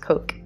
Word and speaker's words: Koch: I think it's pronounced Koch. K-O-C-H.